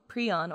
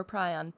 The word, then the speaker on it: prion: When you Google prion or prion, both like the protein fold that kills you come up, and also like some species of bird in the southern hemisphere. – I've always pronounced it and heard it as prion. – Me too, but yeah. I think both are acceptable.